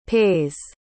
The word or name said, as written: pays